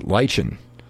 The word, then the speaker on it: Lechien: If Emil goes by the pronunciation of the actual word, L-E-C-H-I-E-N, then you're going to know how to do it. – There we go. – She says Lichen. It says she's got L-I-E-S-H-E-N. Lichen.